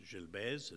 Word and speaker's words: Gilbert's: So it's pronounced Gilbert's, it's spelled G I L B E R T apostrophe S.